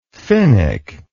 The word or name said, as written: finnick